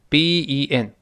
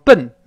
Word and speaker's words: Ben: Call Ben B E N，不要读成笨啊，它不是中文发音笨那个声音，中间它发哎这个声音，稍微往两边分开一点我们读一下 Ben。